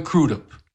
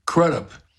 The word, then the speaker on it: Crudup: Arthur Big Boy Crudup. When I was a kid, I used to thought his name was Crudup, but I found out later that he pronounces it Crudup.